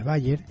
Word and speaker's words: Bayer: El que figura como comprador, Bayer, pronúnciese Bayer, en nuestro contrato es la subsidiaria de una de las mayores compañías químicas del mundo.